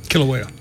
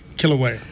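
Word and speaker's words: Kīlauea: Hawaii, the volcano, I wish I could pronounce this the way they do, Kilauea, whatever. – Kilauea. – Thank you. Yeah, so when I point to you, you say the name. Hawaii volcano. – Kilauea.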